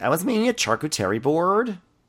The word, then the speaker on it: charcuterie: Like, I was making a chakut array tray.